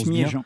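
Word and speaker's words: Smadja: J'espère que je prononce Smiedjan. – Bien. Smiedjan, pardonnez-moi.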